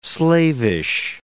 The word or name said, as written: slavish